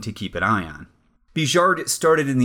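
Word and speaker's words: Bigeard: The other man, whose name is spelled in English, Big-Eared, but who will be appearing more and more, so I should probably start pronouncing his name correctly, which I know now is correctly, because now I have checked, Bijard.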